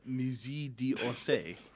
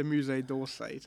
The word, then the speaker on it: Musée d'Orsay: Musée d'Orsay, which is in... – that would be the Musée d'Orsay to most people.